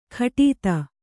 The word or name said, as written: ಘಟಿತ